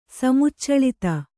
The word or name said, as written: ಸಮುಚ್ಚಳಿತ